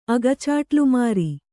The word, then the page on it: ಅಗಚಾಟ್ಲುಮಾರಿ: Kannada